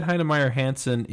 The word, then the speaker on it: Heinemeier Hansen: Okay, it's by Jason Fried and David, I'm going to say this wrong, Heinemeier Hansen? – Yeah, David Heinemeier Hansen is the creator of Ruby on Rails, which is the framework that I work in.